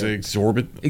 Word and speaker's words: exorbitant: Is that how you say it? Exorbitant or exorbitant? – It's exorbitant.